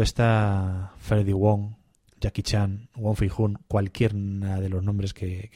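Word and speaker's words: Wong Fei-hung: Sí, Wong Fei-hun.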